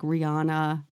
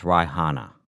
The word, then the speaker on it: Rihanna: I think it's saying it wrong. Anyway, that's what I could think of. I don't know if you fall into a sinkhole, are you gone forever? – It's actually pronounced Rihanna.